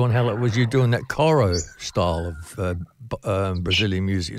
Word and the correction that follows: choro: Is it coro? – Pronounced choro. – Yeah, choro.